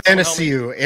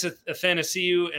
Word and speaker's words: Athanasiou: We'll sign Anastasiou. Is that how you pronounce it? – Athanasiou. Andreas Athanasiou. – Andreas Athanasiou.